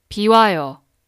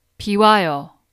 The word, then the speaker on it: 비와요: 비와요. 비와요. 비와요.